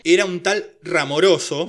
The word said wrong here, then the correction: Ramaroso: ramaroso. – Ramaroso, perdón.